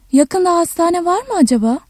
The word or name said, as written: yakında